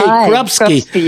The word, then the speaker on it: Krupsky: That's Krupsky, correct? – S-K-I. – Okay. – Krupsky. Yeah.